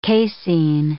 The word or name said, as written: casein